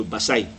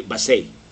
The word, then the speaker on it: Basay: Ang pronunciation na naliyo Basay, dili Basay. So Basay, Samar.